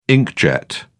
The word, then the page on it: inkjet: British English